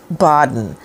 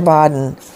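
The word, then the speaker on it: Baden: And it's B-A-D-E-N, although you pronounce it Bodden. Dr. Bodden, it's always an honor and a privilege to have you on with us.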